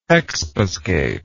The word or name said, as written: expiscatory